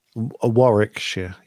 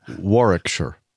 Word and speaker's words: Warwickshire: You don't say second W. – Warwickshire. There we go. Yeah. Of course. Why would you pronounce it? – Why would you have it? – Why would you pronounce the first one? – Yeah, that's what I'd like to know.